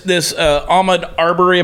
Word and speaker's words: Ahmaud Arbery: This Ahmaud Arbery, am I saying his name right? – Yeah.